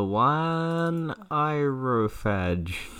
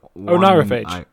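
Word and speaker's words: oneirophage: The one irophage. – That's definitely not how you say that word. – The O-N-E-I-R. – Hang on. – P-H-A-G-E.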